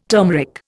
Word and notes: turmeric: Indian

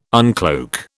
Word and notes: uncloak: British English

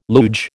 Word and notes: luge: American English

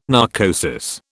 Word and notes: narcosis: British English

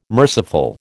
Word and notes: merciful: American English

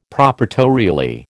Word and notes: proprietorially: American English

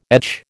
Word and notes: etch: American English

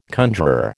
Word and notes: conjuror: American English

